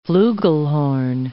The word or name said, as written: flugelhorn